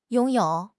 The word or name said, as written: 拥有